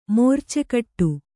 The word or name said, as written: ಮೋರ್ಚೆಕಟ್ಟು